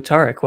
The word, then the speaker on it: Tariq: So Tariq, welcome to the podcast. – Thank you, for having me. Happy to be here. – And your name is pronounced Tariq, for the record.